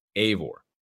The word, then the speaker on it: Eivor: First off, too, Ashraf over on Twitter says that it's spelled E-I-V-O-R and pronounced Eivor, A-Y. – Yep. – Yeah, Eivor.